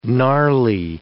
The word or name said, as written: gnarly